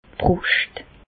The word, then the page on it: d' Brùscht: Bas-Rhin; Reichshoffen